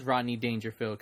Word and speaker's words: Rodney Dangerfield: And I don't know, I feel like Ronnie without the D, huh? – Oh, I said it fast. Ronnie Dangerfield. Ronnie. I say it fast. I have to pronounce it. – And your dad loves Rodney Dangerfield, because I remember he talked about that.